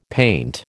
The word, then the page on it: paint: American English